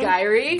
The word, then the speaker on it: Jerry: How do you spell that? – Like Jerry. – It's just spelled, it's J-E-R-R-Y. – Yeah. – And you pronounce it. – So it is a J. – Yeah, it's a J. – It's a J, so, but it's somehow Gy-rie. – Yeah. So it's like Jif.